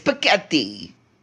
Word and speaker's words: Piketty: But that's why I said Thomas Piketty. But it sounds like Thomas Spaghetti. But which made me question that choice. – Yeah, this is not... Yeah. – No, it rhymes with spaghetti. It's Piketty.